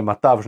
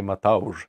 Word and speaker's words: Matavž: S obzirom, je na V, jer nije Matavž, nego je Matauž.